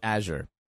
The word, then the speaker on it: Azure: So in the U.S., it's pronounced Azure. We went and we looked it up. We looked it up in like 10 dictionaries and whatever, and the word was Azure, with emphasis on the first one. Everywhere else in the world, like every other country, it's Azure.